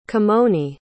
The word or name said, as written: kimoni